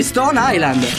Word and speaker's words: Stone Island: Di Jim, che c'è la questi jeans della Stone Islands. – Stone Island. – Ma perché scrivono? Scrivono come si legge. – Si chiama così, Stone Island.